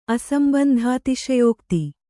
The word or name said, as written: ಅಸಂಬಂಧಾತಿಶಯೋಕ್ತಿ